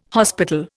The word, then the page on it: hospital: Indian